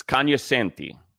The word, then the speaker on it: cognoscenti: It's cognoscenti. The G is silent.